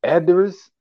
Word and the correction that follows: Aldis Hodge: Aldis Hodge.